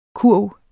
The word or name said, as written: kurv